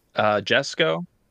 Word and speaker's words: Jesko: Jesko, I believe is the pronunciation. – Jesko, because that's his first name.